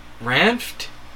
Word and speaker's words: Ranft: Joe Ranft? – Yeah, you got that right. – There's three syllables. There's three consonants there that don't usually go together.